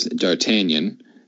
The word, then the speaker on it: D'Artagnan: And the last of the four is D'Artagnan. I do know that is the correct pronunciation.